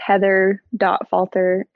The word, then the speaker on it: Heather Falter: And my name, it's Heather.Falter, and my last name is spelled F-A-L-T-E-R.